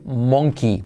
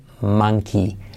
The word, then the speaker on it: monkey: Un ejemplo claro que aquí no está, pero que te lo digo, en los colegios nos enseñan a decir monkey, porque se escribe monkey, pero se dice monkey, monkey. monkey. Es un ejemplo claro que simplemente con acordarte de mano, man, man, man, monkey, ya lo tienes, ¿vale?